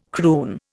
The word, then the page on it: croon: Indian